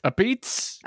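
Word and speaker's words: Apizza: Cheryl and Richard Bartone, a mother and son who own Bartone's A Pizza, or as I believe we've been told to pronounce this. – A Pizza.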